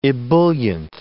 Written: ebullient

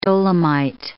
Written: dolomite

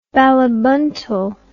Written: balibuntal

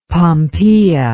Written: pompeia

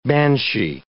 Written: banshee

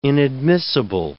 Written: inadmissible

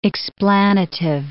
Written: explanative

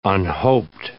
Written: unhoped

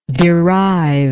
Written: deriver

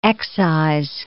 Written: excise

